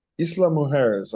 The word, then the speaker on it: Isla Mujeres: Yesterday, got there around noonish, and then you take a ferry over here to Isla Mujeres. I guess they say. Isla Mujeres, I think is the what I'm calling the local term. Like that's how the locals say it.